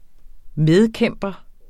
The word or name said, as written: medkæmper